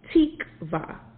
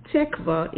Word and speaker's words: tikvah: So as I went through, the Hebrew word for hope is the word tikvah, and it's spelled T-I-K-V-A-H.